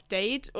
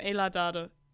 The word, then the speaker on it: Ayla Dade: Wie spricht man jetzt den Nachnamen? Weil manche sagen auch Date und... aber Dade, Ayla Dade. Nee. Und nicht Ayla, sondern Ayla Dade.